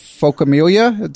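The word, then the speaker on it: phocomelia: Okay, and on your blog, you mentioned the actual medical condition, which is quadrilateral focamelia. Did I say that right? – Yes, you did.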